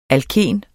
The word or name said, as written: alken